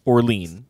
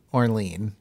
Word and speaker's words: Orlean: Because I read The Orchid Thief by Susan Orlean, or Orlean. – Orlean, as Americans pronounce it. – I think as she pronounces it.